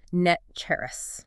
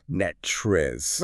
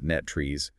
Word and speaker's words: NTREIS: It's spelled N-T-R-E-I-S. But our sources explicitly point out that locals pronounce it Netcheris. – Netchris. – Right. The last syllable ends in a rus sound, exactly like the word rust, Netrus. – It functions as the ultimate local shibboleth. Cool. If you walk into a brokerage and say N-T-R-E-I-S letter by letter, or try to pronounce it Netrees, everyone in the room instantly knows you're either new to the area or relying entirely on internet research without local context.